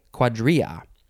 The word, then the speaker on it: cuadrilla: Okay, so the first word in this episode is cuadrilla, or cuadrilla, if you want to put that L sound in there, but that's not the right way to say it. I mean, it's a way to say it. It's not the Spanish way to say it. It is spelled C-U-A-D-R-I-L-L-A, cuadrilla.